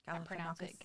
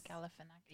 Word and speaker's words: Galifianakis: I don't know how to say his last name. – Galifianakis. – Galifianakis. Yeah.